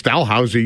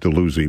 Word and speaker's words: Dalhousie: and there's Port Dalhousie down near St. Catharines, but I've always known that it's Dalhousie University in Halifax, so I don't know why this particular listener always wanted to tell me it should be pronounced Dalhousie, but And by the way, Dalhousie University is older than the country.